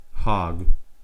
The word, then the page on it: hog: American English